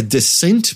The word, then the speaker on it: Descent: Eu acho que a pronúncia correta é Descent, mas eu chamava de Descent.